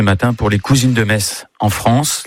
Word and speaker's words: Metz: Le meilleur moyen de mémoriser la prononciation correcte de la ville de Metz est certainement de se rendre au stade Saint-Symphorien, où vous répéterez jusqu'à plus soif.